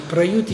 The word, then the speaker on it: prayuti: If by any absence of thought, yad manasav prayuti, prayuti is from root yu to separate. Yeah? Prayuti is adverbial use, like Tritiya Vibhakti, yes, this long E at the end, Prayuti, by the tongue, Jihvaya.